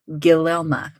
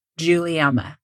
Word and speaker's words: Guilielma: The woman I'm about to tell you about, her name is spelled Guilielma. And if I hadn't already heard it said, I would definitely have pronounced that like Guilielma or something, right? – Yeah. – But instead, this time, I 100% know. – You Googled it. – How to pronounce it. Guilielma. That G is like a J.